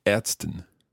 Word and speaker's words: Ärztin: Different vowel sound there. Die Ärztin. – Yes, A, Arzt und Ärztin. The A sound.